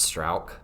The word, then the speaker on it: Struijk: I had to write it down how you pronounce it, because if you look at it, it's like Struijk, and it's pronounced Strauch.